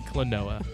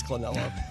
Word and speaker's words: Klonoa: It's Klonoa, and yes, I know. I'll say it properly. Klonoa. – It is Klonoa.